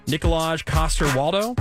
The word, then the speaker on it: Nikolaj Coster-Waldau: Nikolaj Coster-Waldau. Is that how you say his name? – Yeah, he's Danish.